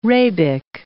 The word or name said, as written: rabic